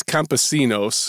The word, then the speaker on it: Campesinos: The first one is from Los Campinos. I don't speak any type of Spanish, so I hope I pronounce that correctly. – I think it's Campesinos.